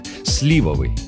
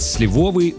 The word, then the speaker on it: сливовый: Так и хочется поставить ударение на «о», правда? Но нет. «Сливовый» от «сливы» и только так. Именно этот вариант орфоэпический словарь русского языка, как государственного, считает единственно правильным. Вариант «сливовый» во всех словарях специально помечен как ошибка.